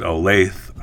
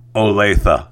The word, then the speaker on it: Olathe: And I probably pronounce it Olathe or Olathe, but according to Tanya, it's pronounced Olathe, Kansas.